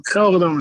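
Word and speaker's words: GelreDome: In Arnhem, Netherlands, at the Yaerdome. Is that, did I pronounce that correctly? – Kelderdome is called.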